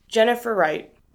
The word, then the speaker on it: Jennifer Wright: Jennifer Wright. – Would you spell that, please? – Jennifer is J-E-N-N-I-F-E-R. Wright is W-R-I-G-H-T.